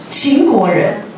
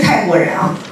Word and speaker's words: 秦国: 秦国人，不是泰国人啊，很多人念成泰国人。